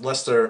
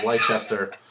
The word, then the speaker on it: Leicester: They were so bad, I didn't even know that it was pronounced Lester and not Leicester. Like, because that's how it's spelled. It's spelled Leicester.